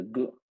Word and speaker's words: Gu: What is the correct pronunciations for the two rain seasons? – The Gu, which is the spring, the Dyer, which is fall.